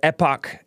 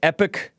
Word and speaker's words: Epoch: I was pronouncing it epic, but I've heard it pronounced epoch. But it's not supposed to be pronounced epoch unless you're British or stubborn, which is cool. Or ignorant, which is also fine, too.